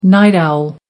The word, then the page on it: night owl: American English